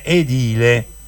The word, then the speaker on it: edile: Però non si dice edile, si dice edile, ricordatelo, non fa che poi trovi una ragazza che conosce bene l'italiano, dice: "Ma questo non lo sa che si dice edile?" L'accento è edile.